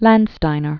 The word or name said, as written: Landsteiner, Karl